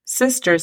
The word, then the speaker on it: sister: Sister significa hermana. La pronunciación fonética de esta palabra sería S-I-S-T-R, sister. Cuidado, al pronunciar la S, no añadamos la E antes de sister.